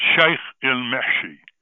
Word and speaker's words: Sheikh al-Mahshi: It's Sheikh Al Mahashi. – Meshi. Yeah. That's a favorite. That's my favorite. It's called Sheikh al-Mahshi. – I knew I mispronounced it here.